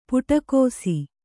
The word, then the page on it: ಪಿಡಿಕೈಸು: Kannada